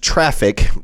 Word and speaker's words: Traefik: And this new tool, which they say is pronounced traffic, but it's spelled T-R-A-E-F-I-K, which I'm going to assume means traffic.